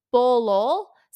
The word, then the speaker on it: Bulul: These statues are called the, I'm going to pronounce this wrong, they're called the Bulul, so B-U-L-U-L.